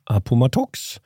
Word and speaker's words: Appomattox: Lee försöker då nå den här järnvägskruten Appomattox. Det är extremt svårt att uttala. – Jag tycker du klarar det bra.